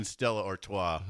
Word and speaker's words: Stella Artois: Drinking Stella Artois.